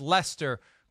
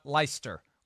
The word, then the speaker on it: Leicester: it's Leicester, Chris, not Leicester or whatever it is that you said.